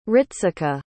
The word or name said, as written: ritsuko